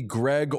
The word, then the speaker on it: Greig: I'm in the middle of my show, and there is a lot of hubbub about the pronunciation of Ridley Greig or Greig's last name.